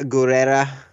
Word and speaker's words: Gurira: Is that Gurira? Am I saying that right? – Yeah, Danai Gurira. – Gurira. Ooh, I can't roll R, so that's a problem.